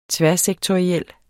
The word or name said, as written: tværsektoriel